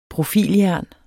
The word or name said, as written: profiljern